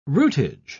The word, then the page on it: rootage: British English